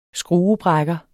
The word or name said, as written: skruebrækker